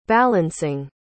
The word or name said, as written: balancing